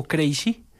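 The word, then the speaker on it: Krejčí: Un lateral de Zufal, el lateral que era el West Ham, ahora está en Alemania, y centro a Crazy, el loco Crazy, se escribe K-R-E-J-C-I, pero le dicen Crazy, conocido popularmente como el loco.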